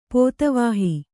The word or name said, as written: ಪೋತವಾಹ